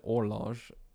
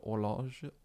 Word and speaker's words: horloge: Well, I think you could pronounce it horloge. That's the French word for clock. So horloge, horologiste.